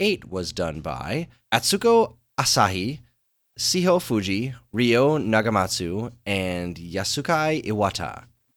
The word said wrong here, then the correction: Yasuaki: How do you say that one? – Yasuaki.